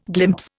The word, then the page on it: glimpse: Indian